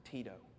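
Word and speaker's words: Tito (Τίτῳ): It's actually in the Greek, in verse 4, the name is pronounced Tito.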